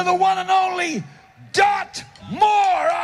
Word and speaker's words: Moore: Por ejemplo, la estupendísima Dot Moore. Yo lo hubiera pronunciado como Moore porque hay dos O's, pero el maestro de ceremonias, que es anglófono el hombre, uno de los músicos, pues dice Moore, así que yo, donde hay capitán no manda marinero, obviamente.